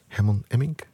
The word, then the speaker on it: Herman Emmink: Destijds groot geworden door Herman Emmink, zeg ik het goed? Jawel.